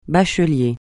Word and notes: bachelier: francais

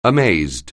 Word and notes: amazed: anglais

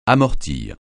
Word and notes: amortir: francais

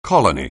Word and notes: colony: anglais

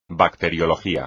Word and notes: bacteriología: espagnol

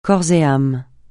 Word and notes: corps et âme: francais

